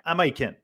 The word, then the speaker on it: Amaiquen: Est-ce que je prononce bien ton nom, Amaikwen? – Amaikwen.